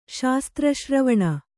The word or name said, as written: ಶಾಸ್ತ್ರಶ್ರವಣ